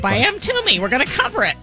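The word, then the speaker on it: Mtume: Is it M. Toomey? How do you say it? – M. Toomey. Just like that.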